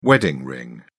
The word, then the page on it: wedding ring: British English